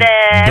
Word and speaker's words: Dourdan: Okay and uh, and of course Gary uh, Dor uh, I can't say his name Gary Dourdan Dourdan, Dourdan.